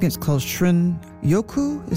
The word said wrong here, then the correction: shinrin-yoku: Is that how you pronounce it? The Japanese term for wood air bathing. – Shinrin-yoku.